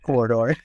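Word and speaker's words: Quoridor: Corridor, yep. – What is it about that game that compares to running a business? – My girlfriend's going to laugh hysterically when she hears the match at Corridor in a business interview.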